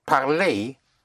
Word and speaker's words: parler: When you have an ER, then ER is pronounced A. Like parler is to speak.